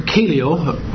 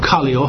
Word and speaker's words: kaleo: And it is derived from the verb kaleo, or kaleo, however you want to pronounce it, K-A-L-E-O, which just means to call.